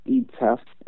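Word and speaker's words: Ietef: That's pronounced Etef, and that's spelled I as in Isaac, E as in elephant, T as in triumph, E as in elephant, F as in father.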